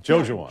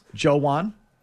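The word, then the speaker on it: Joejuan: Okay, it's spelled J-O-E-G-J-U-A-N, Joe Jawan. – Joe Jawan. – J-U-A-N is Juan. Like Juan Uribe or Juan Gonzalez. – Joe Juan.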